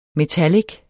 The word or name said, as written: metallic